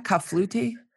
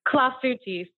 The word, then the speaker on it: clafoutis: How do you say that? Clafouti? – Clafouti. C-L-A-F-O-U-T-I-S. So cherry clafouti. Someone told me it sounds like a disease.